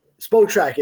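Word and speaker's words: Spotrac: If you look up on like the over the cap side on SpotRack or SpoTrack, I don't know how you pronounce it, but SpoTrack. Okay, confirmed. It's SpoTrack.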